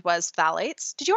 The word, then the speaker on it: phthalates: Technically, there are supposed to be they're phthalates. You could kind of you roll right over the th and go straight to the th, but you are supposed to pronounce both of those sounds.